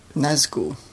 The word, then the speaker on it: Nazgul: Nargol out of the sky. That's pretty good. A flying Nargol. Or Nargul. How do you pronounce it? – Nazgul. – Nazgul. That's what I'm thinking of.